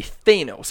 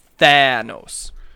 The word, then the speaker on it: Thanos: If there was an E at the end of it, right, like, if there was an E, it would be Thanos, because then you pronounce the A. There's no E at the end of it, so it's the A. Thanos.